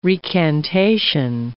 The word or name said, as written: recantation